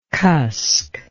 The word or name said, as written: cusk